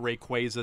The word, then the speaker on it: Rayquaza: But Rayquaza is the official pronunciation.